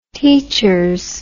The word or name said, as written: teachers